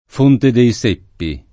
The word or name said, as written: Fonte dei Seppi